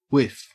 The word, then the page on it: whiff: Australian English